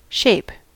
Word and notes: shape: American English

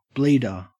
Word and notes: bleeder: Australian English

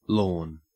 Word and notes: lorn: Australian English

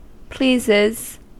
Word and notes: pleases: American English

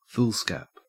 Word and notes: foolscap: Australian English

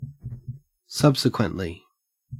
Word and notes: subsequently: Australian English

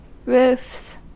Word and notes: roofs: American English

